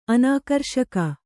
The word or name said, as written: ಅನಾಕರ್ಷಕ